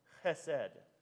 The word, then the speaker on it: chesed: It starts with a C and it's pronounced chesed.